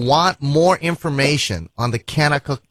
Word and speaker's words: Kanakuk: Canna Cook is spelled K-A-N-A-K-U-K. Canna Cook. Everything with a K there.